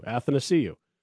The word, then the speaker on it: Athanasiou: It rhymes with happy to see you. Athanasiou. Not hard.